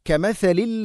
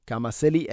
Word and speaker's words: كَمَثَلِ: കമസലിൽ ലദീന മിൻ ഖബ്ലിഹിം. കമസലി അല്ല കമസലി, ഉച്ചാരണം പഠിക്കുക. കമസലി, നാവിൻ്റെ അറ്റം മുൻപല്ലുകളുടെ ഇരു നിരകൾക്കിടയിലൂടെ അല്പം പുറത്തോട്ട് വരണം.